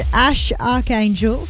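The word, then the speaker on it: X Æ A-Xii: It's still pronounced Ash Archangel, so don't worry.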